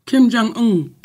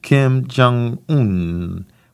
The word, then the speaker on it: Kim Jong-un: Kim Jong-un. – Kim Jong-un.